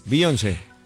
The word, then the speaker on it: Beyoncé: ¿Cómo se pronuncia? – Sí, sí, sí, Beyoncé.